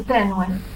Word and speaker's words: Tenue: A ver se tiño interese por saber que mándenos a compañeira Ana Tenue Punch, o pronuncio ben ou? – Bueno, chamanse Tenue.